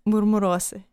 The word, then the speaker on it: Murmurosis: Murmurosis, c'est-à-dire comment ça se prononce? – C'est un mot pas vrai, mais... On prononce ça comme murmurose.